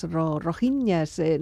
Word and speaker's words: rohingyas: Venimos para hablar de la situación de una etnia, una minoría étnica que se llaman los rohingyas, rohingyas, no sé exactamente cómo se pronuncia esto. – Sí, lo has pronunciado bien.